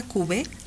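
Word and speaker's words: Cube: que se pronuncia Cuvé, Cuvé Bonifant,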